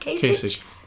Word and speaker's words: Kasich: Kasich.